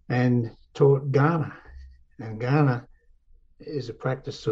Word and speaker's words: Gan'na: There's a word Gan'na, G A N apostrophe N A, Gan'na is how you say it,